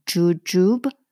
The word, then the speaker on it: jujube: So glutinous uh, sweet rice with chestnut, pine nut, jujube, 대추, juju 장음이에요. jujube 이렇게 나죠.